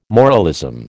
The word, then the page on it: moralism: American English